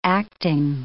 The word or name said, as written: acting